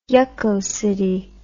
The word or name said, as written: yuckocity